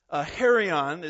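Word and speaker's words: hieron: Herion is how you pronounce that word, I believe.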